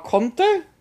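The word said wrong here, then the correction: Condé: Conde. I think it is. Yeah. – Conde, yeah. – The fate of the president, Alpha Conde, is unclear after an unverified video showed him surrounded by soldiers who say they have seized power of the country.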